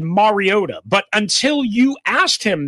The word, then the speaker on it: Mariota: Say it right, Mariota.